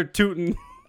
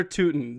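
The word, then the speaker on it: Tyutin: Fedor Tiutsian. – It's Fedor Tootin. – Oh, see, I tried to like... – Nah, that didn't work. All right. – Fedor Tootin.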